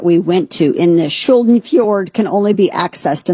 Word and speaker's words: Skjolden: In Norway, I was all the way up, don't laugh at my pronunciation, in a town called Skjolden, which is spelled with a K. Skjolden has a K in it.